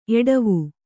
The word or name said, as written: ಎಡವು